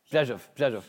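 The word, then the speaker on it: Brzozów: Borova, B-R-Z-O-Z-O-V. – Brzezow. Yeah. RZ in Polish pronounced like a Zh.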